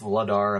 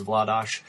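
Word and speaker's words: Vladař: He you know, Darth Vladar. I mean, people say Vladar, Vladash. She's even said Vladar, but really the Czech pronunciation is Vladash.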